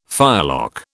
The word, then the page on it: firelock: British English